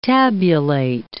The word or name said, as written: tabulate